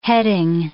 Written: heading

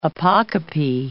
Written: apocope

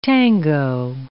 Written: tango

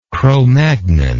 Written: cromagnon

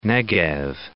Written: Negev

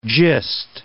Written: gist

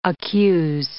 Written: accuse

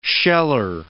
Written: sheller

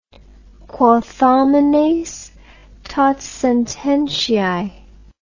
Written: quot homines tot sententiae

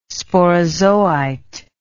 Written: sporozoite

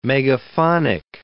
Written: megaphonic

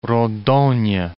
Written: Rondonia

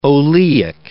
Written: oleic acid

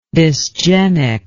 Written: dysgenic

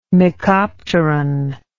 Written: mecopteran

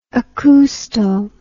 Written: acousto

